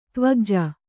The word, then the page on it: ದೇವಕುಜ: kannada